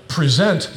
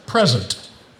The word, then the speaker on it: present: But if you put the emphasis on a different syllable, present becomes present.